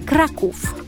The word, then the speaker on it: Kraków: Okay, to clear this up, the correct way to pronounce the name of the city is Kraków.